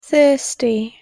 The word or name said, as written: thirsty